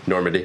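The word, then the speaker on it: Normani: And then what's the other Normandy? – Normandy. – Is that how you say it? – Yeah.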